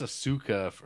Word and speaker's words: Ahsoka: I think it's Ahsoka on Star Wars, but it's Asuka if you watch Neon Genesis.